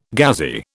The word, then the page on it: Ghazi: British English